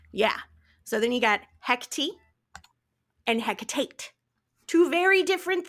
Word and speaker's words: Hecate: And it isn't it is pronounced Hecate, not Hecti, which has become much more popularized lately in the last four or five years.